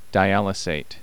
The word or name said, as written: dialysate